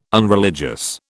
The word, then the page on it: unreligious: British English